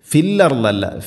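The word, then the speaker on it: ഫിൽ അർദ് (في الأرض): വയുഫ്സിദൂന ഫിൽ അർദ്, ഫിൽ അർദല്ല ഫിൽ അർദ്, ഫിൽ അർദ് ഫിൽ അർദ്. വഖഫ് ചെയ്യുമ്പോൾ അർദ് ഇങ്ങനെ ദാദിനെ പ്രത്യേകം ശ്രദ്ധിച്ച് ഉച്ചരിക്കണം.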